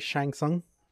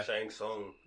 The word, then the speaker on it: Shang Tsung: According to Google's, I think you guys said it right, Shang Tsung. – Yeah. – Shang Tsung.